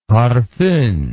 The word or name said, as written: parfum